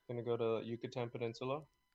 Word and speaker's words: Yucatan: Yucatan, yeah.